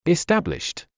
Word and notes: established: istǽbliʃt; イスタブリッシュト

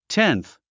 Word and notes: tenth: ténθ; テンス

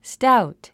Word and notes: stout: stáut; スタウト

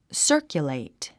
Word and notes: circulate: sə́ːrkjulèit; サァキュレイト

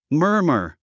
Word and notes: murmur: mə’ː r mə r; マーマァ

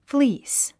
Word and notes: fleece: flíːs; フリース

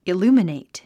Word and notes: illuminate: iljúːmənèit; イルーマネイト

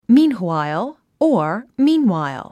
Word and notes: meanwhile: míːn h wàil; ミーンホワイゥ

